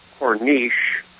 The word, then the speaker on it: Corniche: Let me try version number one, Cornish. And the second version is Corniche. It is spelled C as in cat, C-O-R-N-I-C-H-E. C-O-R-N-I-C-H-E.